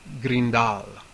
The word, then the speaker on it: Grindal: It's not, it rhymes with brindle, but it's not spelled like brindle. It's spelled like Grindal.